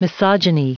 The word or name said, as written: misogyny